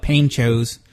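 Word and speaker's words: Pancho's: but the OG ponchos or panchos, how is it pronounced? – Well, you fellas, being from the liberal northeast northlands, whatever you call them mm-hmm. up there, you Yankees, we call it panchos.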